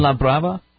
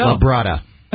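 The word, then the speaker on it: Fuenlabrada: Inside of a Spanish word, the D is pronounced with a feta. – Ah, gotcha. So there you go. – La Brada.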